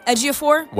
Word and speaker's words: Ejiofor: Chiwetel Ejiofor. Ejiofor.